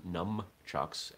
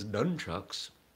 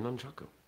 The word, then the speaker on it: nunchaku: I do reject the term numchucks, as in N-U-M or N-U-M-B, because I think that's ridiculous. I'm okay with a sort of English version of the word as nunchucks. But they're nunchaku.